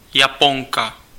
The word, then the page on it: Japanese: American English